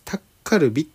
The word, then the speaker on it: タッカルビ: 両方正解なんですよ。なんかね、現地の発音的には、チーズタッカルビっていう方が近いそうなんですけど、なんかスペル的にはね、あの、チーズダッカルビと書くらしいんですよね。